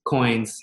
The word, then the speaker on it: Qoins: And a fun fact, like the name Qoins, it's spelled with a Q, right? But originally, it was called Consolidate. – Consolidate. Okay. – Terrible name. I literally still have the notebook that says like consolidate, and I put in parentheses like this is not permanent. And like within a week, I had changed the name because I just did not. I knew it wasn't like something that would really stick. – Yes, and for the listeners, so it's Q-O-I-N-S. – Correct. So, coin spelled with a Q.